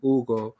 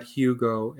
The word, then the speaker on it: Hugo: And I remember complaining to my mother about some bullying that was happening because of how you pronounce my name, Hugo, not Hugo, and the fact that I spoke a different language, that we ate different foods.